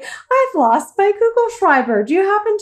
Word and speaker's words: Kugelschreiber: My favorite word in German, and I hope I say it right, Kugelschreiber. – Kugelschreiber.